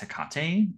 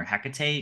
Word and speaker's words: Hecate: And I've been really interested in the goddess Hecate, or Hecate, you can pronounce it both ways, over like the past year or so.